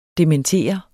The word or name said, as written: dementere